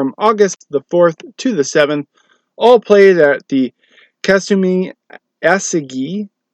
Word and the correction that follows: Kasumigaseki: Ooh, did not say that right. Let's try that again. Kasumigaseki Country Club in Tokyo.